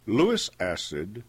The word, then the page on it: Lewis acid: American English